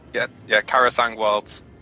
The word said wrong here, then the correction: Krasarang: Oh, it's Krasarang. – Yes, I know, but when we first started talking about Miss Pandaria, we all mispronounced it, and...